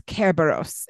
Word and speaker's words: Kerberos: Because I distinctly remember my Latin professor being like, it's Kerberos.